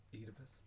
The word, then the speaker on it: Oedipus: And then we had Pedius. Who is it? How do you pronounce it? – Oedipus. – Oedipus, that's it.